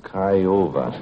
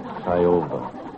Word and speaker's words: Kyowva: Jimmy Hetzer, K-Y-O-W Virginia Fair Corporation. He says, how do you pronounce K-Y-O-W-V-A? – I don't think you do. – Kiowa. Kiowa. He says,